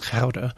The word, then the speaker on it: Gouda: And there were even local representatives of this movement in Delft and what we call Gouda, which is pronounced Gouda.